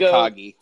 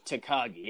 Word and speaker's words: Takagi: I can't ever say his name. Shingo Takagi. – Takagi, yes.